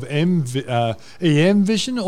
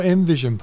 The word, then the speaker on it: EMvision: He's the CEO of EMvision or EMvision, Paul? – Well, I did. I actually interviewed Ron. Look, he said you could pronounce it either because I asked him, I was saying EMvision, then he said EMvision.